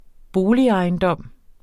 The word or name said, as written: boligejendom